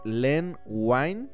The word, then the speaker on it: Len Wein: Justamente eh, fue creado por Lin Wine y Bernie Wrightson. Para los que no sepan quién es Lin Wine, fue Len, Len Wine. Yo siempre lo pronuncio como Lin Wine.